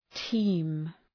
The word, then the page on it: team: ti:m